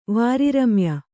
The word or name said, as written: ವಾರಿರಮ್ಯ